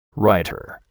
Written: writer

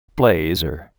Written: blazer

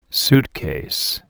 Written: suitcase

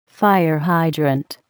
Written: fire hydrant

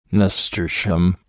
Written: nasturtium

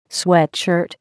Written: sweatshirt

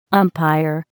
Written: umpire

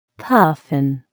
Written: puffin

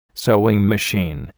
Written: sewing machine

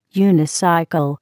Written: unicycle

